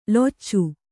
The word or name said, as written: ಲೊಚ